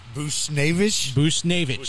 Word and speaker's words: Buchnevich: Buchnevich. – Sounds like booty sniff.